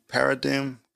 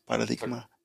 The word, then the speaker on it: paradigm: So, in your journey, you know, like as a single mom, you're reshaping, like, kind of like, I would say the paradigm, how do you say that word? Paradigma? – Paradigm. – There we go.